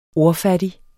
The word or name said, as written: ordfattig